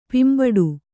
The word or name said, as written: ಪಿಂಬಡು